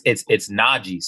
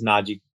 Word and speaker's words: Najee: So Najee, like, you listen to, you probably don't listen to saxophone music, but there's a saxophone player named Najee. And that's how you pronounce my name. It's not hard.